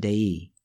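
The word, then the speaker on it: DEI (δεῖ): the little Greek verb being translated as must, or it is necessary, delta epsilon iota, or DEI, pronounced DEI, makes its first appearance in the story right here.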